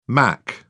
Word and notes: mach: British English

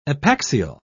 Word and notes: epaxial: British English

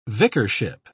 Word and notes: vicarship: British English

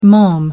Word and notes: mom: American English

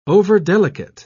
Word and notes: overdelicate: British English

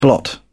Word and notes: blot: British English

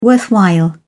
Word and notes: worthwhile: British English

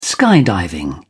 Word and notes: skydiving: British English

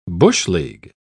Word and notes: bush-league: British English

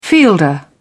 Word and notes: fielder: British English

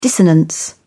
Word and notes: dissonance: British English

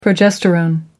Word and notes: progesterone: American English

